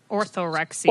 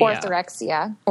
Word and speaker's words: Orthorexia: And then there's, how do you say it again? – Orthorexia. – Orthorexia.